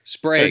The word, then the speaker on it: Sprague: How do you say Sprague. – It? Sprague.